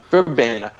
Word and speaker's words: Verbena: There was a Verbena, how do you say that? – Verbena.